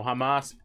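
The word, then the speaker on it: jamás: Because in case of you idiots, I didn't know, Hamas is a Spanish word. It's spelled J-A-M-A-S, means never.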